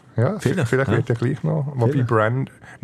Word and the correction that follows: Braden: Nein, Braden, nicht Brandon. Braden 100 Mark. – Das Einzige, was er jetzt verstanden hat, dass wir fünfmal seinen Namen falsch gesagt haben. – Braden, also gell, also B-R-A-D-E-N spricht man auch Braden aus.